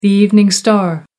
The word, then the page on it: evening star: American English